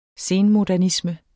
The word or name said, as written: senmodernisme